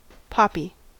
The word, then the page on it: poppy: American English